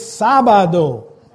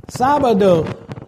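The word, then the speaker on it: sábado: It's Sabado. Sabado.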